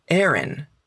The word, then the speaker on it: Aaron: I'm talking about Aaron. Muy bien, y es raro porque este nombre se escribe con dos A, pero igual se pronuncia Aaron.